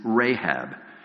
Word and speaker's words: Rahab: Her name, as we pronounce it, is Rahab.